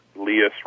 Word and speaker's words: Leus: When I tell people how to pronounce my last name, my favorite rhyme is Leus rhymes with Prius.